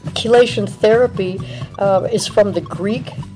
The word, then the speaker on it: chelation: But the reason is that chelation is spelled C-H-E-L-A-T-I-O-N. So the C-H is very seldom. Can you think of any words that have a C-H that are pronounced with a K? – No.